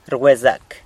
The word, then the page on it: rgwezac: sanlucasquiavini